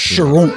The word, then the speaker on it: Charentes: I was focusing on that very hard to pronounce French white wine cask that you were talking Charentes.